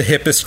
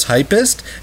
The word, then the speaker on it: hippest: hippest club around. Yeah. And we see Marika reading this poster and she pronounces the word hippest as hypest, and it's never called out.